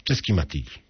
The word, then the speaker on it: Csikszentmihalyi: Redaktorzy paryskiego tygodnika piszą nazwisko Węgra wykładającego w Kalifornii tak. Michali Csikszentmihalyi, co pewno czyta się w macierzystym języku tak. Michał Csikszentmihalyi. Otóż Michał Csikszentmihalyi napisał wiele książek o tym, jak być szczęśliwym.